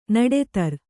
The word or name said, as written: ನಡುದಾರಿ